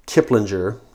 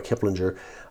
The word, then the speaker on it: Kiplinger: So according to Kiplinger, I believe is how you pronounce it, not Kiplinger, but Kiplinger, millennials prefer boutique restaurants, and for fast food, go for fast casual places like Chipotle, which, by the way, I love Chipotle.